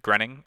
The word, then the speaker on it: Groening: Because with every other Matt Groening, and I may be pronouncing his name wrong. – Groening.